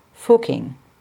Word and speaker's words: Fucking: Indeed, the place is called Fucking. In case you were wondering, it is pronounced with a closed U, as in put.